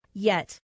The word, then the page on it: yet: female